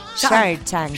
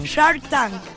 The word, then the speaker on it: Shark Tank: Shark Tank. – Shark Tank. Shark Tank.